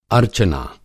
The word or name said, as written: अर्चना